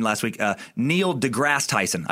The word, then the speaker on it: DeGrasse: I've been calling him Degrassi as if he's the fucking star of Degrassi Junior High because I don't know how names work. As is pointed out by time sucker JT Henry, it's DeGrasse. No E sound at the end.